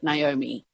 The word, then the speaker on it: Naomi: Let's take Naomi. – Okay. – There are two syllables in there, Na and Omi. – Uh-huh. – And how do we join them as English Naomi. Speakers? With a Y, okay.